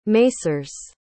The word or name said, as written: masers